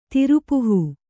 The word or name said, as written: ತಿರುಪುಹೂ